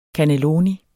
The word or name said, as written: cannelloni